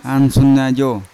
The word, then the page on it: ansunajo: Yawa; Unat